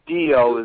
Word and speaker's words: Dio: That was by Dino, isn't it? Is that how you say their name? I forget. – Dio is how you say the name of that band. – Dio.